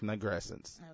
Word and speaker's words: nigrescence: But for now, we're going to focus on William Cross today, which is the nigrescence. Am I saying that correctly? – Yes, you are. You're saying it's nigrescence.